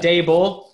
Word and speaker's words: Daboll: How do you pronounce his name? Is it Dable? – Yeah. – I'm going, I think my guy currently is Brian Daboll, who has been the Bills offensive coordinator for the past two seasons.